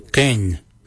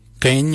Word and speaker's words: Geny: For 1, we say Ken. Ken means 1. Ken is spelled G-E-N-Y, Ken.